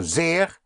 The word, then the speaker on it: sehr: Meer, more, M-E-H-R, or Meer, ocean, M-E-E-R, Meer. So sehr is spelled S-E-H-R.